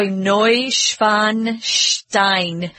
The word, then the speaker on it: Neuschwanstein: So Ludwig, and they like a lot of sh. So Neuschwanstein. It almost sounds like you have a lisp or something, but that's how they pronounce these.